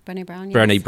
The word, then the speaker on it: Brené: So you mentioned Bree Brown. – Brené Brown, sorry. I'm terrible with pronunciations.